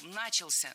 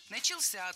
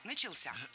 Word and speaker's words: начался: Много раз прозвучал глагол «начался», но это неверно. Правильный вариант – «начался», только так. Ударение в этом слове падает на возвратную часть «ся». «Начался».